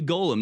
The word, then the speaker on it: golem: We need a golem. No, not the character from Lord of the Rings. Golem with a long O.